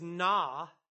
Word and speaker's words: na: The Hebrew word is na. That's how you would pronounce it.